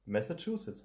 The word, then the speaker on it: Massachusetts: Massachusetts heißt das.